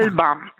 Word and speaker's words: Alban: Alban o Alban? Come lo pronunciamo? – Alban. Alban, perché Alban esiste realmente, è uno dei miei orsetti francesi.